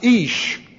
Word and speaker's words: ish: But when you want man in the standpoint of noble man, great man, they have another word that looks like this, and it is pronounced ish. And when you put A-H on there, it's Isha, the woman.